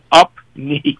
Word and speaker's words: Upneeq: Now, we had talked just a few minutes ago about the upneke, or how did you say that? – Yeah, it's called upneke. It's a little bit strange, but it's a prescription, UPNEEQ.